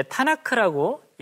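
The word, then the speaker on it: Tanakh: 이 TNK라고 하는 것 이것을 이제 타나크라고 읽습니다.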